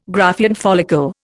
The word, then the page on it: Graafian follicle: Indian